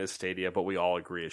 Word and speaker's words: Stadia: The announcement and presentation of Google Stadia has caused two of Slightly Mad's investors to walk away from the project.